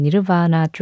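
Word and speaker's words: Nirvana: 네, 원어의 발음은 니르바나 쪽에 좀 더 가깝지만 우리는 90년대에 우리가 자주 듣고 불렀던 그 발음, 너바나로 그냥 발음을 하겠다.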